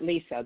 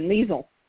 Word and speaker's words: Liese: I've always pronounced it Lisa. That was the way my mother did. It's Liese, a German spelling. Yeah, it's a German spelling, and if there's any German listeners out there, they can tell us if I'm doing it right or if I've said it wrong all my life.